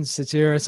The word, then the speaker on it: Sotiris: So welcome to the podcast, Jason Sotiris. I hope I've said that right, mate. – Yes, you have.